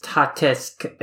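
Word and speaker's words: Tatesc: It's basically, like, it's pronounced Tatesk, and it stands for Tate Vertical and SC as scrolling.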